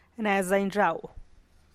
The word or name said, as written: raguz